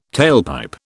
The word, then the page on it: tailpipe: British English